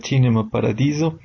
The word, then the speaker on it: Cinema Paradiso: ya pronunciada correctamente en su idioma original sería Cinema Paradiso, va a volver a los cines para celebrar la reapertura de las salas.